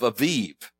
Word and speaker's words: Aviv: All right, this is the year, the month of Aviv, A-V-I-V. Actually, in Hebrew, it's A-B-I-B, but they pronounce B's like V's. So it's the month of Aviv.